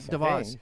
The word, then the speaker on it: DeVos: DeVos.